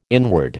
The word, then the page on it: inward: American English